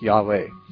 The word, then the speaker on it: Yahweh: And I was wondering about the pronunciation, because you know the Jehovah's Witnesses, they say it's Jehovah. – Well, they pronounce it Jehovah. A lot of scholars say it should be pronounced more like Yahweh.